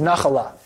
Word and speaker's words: nachala: The Hebrew word for inheritance is nachala.